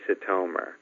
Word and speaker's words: Sitomer: That's Sitomer with a long O sound. And what's funny is when I was a little kid and going to school and all that stuff, people always mispronounced my name. They said it this way, they said it that way, but very rarely did they say Sitomer.